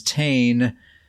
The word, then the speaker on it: Tane: And for listeners, a reminder, my Stam Sork's name is Tane, spelled like Wayne, but with a T.